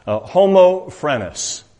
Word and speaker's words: homophrenos: I asked my Greek professor, Mr. Yutzi, how to pronounce it, and he says it is homophrenos. And some of you will Google that real fast, and if I mispronounced it, excuse me for that. But homophrenos, I think you kind of get the sense of homo meaning one, and phrenos really implies inward.